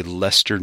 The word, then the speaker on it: Leicester: L-E-I-C-E-S-T-E-R, and if so, how does it get pronounced? – There is one in Massachusetts, and it's pronounced Leicester. – Wow, they got it right.